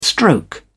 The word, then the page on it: stroke: British English